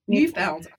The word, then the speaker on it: Neufeld: Neufeld.